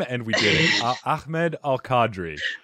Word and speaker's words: Ahmed: Ahmed. That is the proper way to say it. Ahmed Al Qadri.